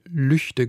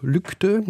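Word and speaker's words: Lügde: Und wenn man davon ausgeht, dass ein deutscher Ortsname so ausgesprochen wird, wie die gewöhnlichen Ausspracheregeln anzuwenden sind, dann würde man erstmal sagen, der Ort heißt Lügte. – Ja. – Weil das G nach einem Vokal als ein G oder ein K gesprochen wird.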